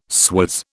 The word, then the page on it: swizz: British English